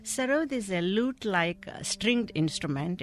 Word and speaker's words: sarod: Now, he is the sarod player? – Sarod player, yes. – Sarod, am I saying that wrong? – Sarod.